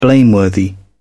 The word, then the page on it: blameworthy: British English